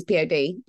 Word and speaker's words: P.O.D.: So Martin and Sam both got in touch and said, hey, is it this one that's called like Boom or Here Comes the Boom, but it's by Pod? Or do you pronounce it P-O-D? – P-O-D, it's P-O-D.